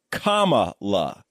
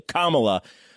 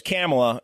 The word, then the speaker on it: Kamala: Guys, so finally, and most importantly, her name is pronounced Kamala, C-O-M-M-A-L-A, phonetically, Kamala. People are having a lot of trouble with this. Me personally, I've also had trouble with this the entire goddamn time, because I always thought it was Kamala.